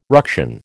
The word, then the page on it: ruction: American English